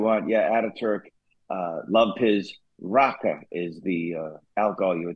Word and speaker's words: raki: You know, there are very similar type drinks around the world, but they love their raka, and that's spelled R-A-K-I, but there's a kind of an A sound at the end of it, a short A sound, raka, and it was delicious.